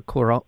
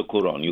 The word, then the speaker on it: Cuarón: And as was it Alfonso, the film director, Cuarón? I'm saying his name incorrectly. – Alfonso Cuarón.